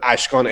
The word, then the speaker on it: Ashkan: We always put Ashkan, A-S-H-K-A-N.